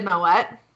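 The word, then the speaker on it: Moët: Yeah, and I like that you pronounced Moet correctly. – Well, yeah. – It's a hard T, people. – It's a hard T because it's not a French family.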